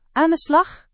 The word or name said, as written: Aan de slag